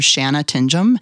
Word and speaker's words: Shanna Tingom: And if you go to Facebook, you'll find me under Financial Advisor Shanna Tingom. And how you spell that is S-H-A-N-N-A, and the last name is T-I-N-G-O-M.